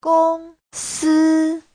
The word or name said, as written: gong1si1